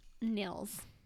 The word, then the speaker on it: Nils: Nils, I don't know how you say his name. – I like Nils. – It's N-I-L-S. – Like Niels.